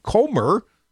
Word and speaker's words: Comer: Jim Jordan, who's expected to become the chair of the House Judiciary Committee and other Republicans on the Oversight Committee, Comer, rhymes with Gomer, said Republicans have made connections between the president's son, Hunter Biden, and the president that they believe requires further investigation.